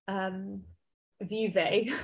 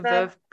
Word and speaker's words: Veuve: I always want to call Vuvé, which is obviously not how you... – Like the Veuve Clicquot one? Clicquot? – Yeah, oh, and I always want to call it Vuvé.